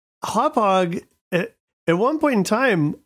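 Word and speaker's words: Hauppauge: That random city in New York. Yeah, it's called Hophog, people.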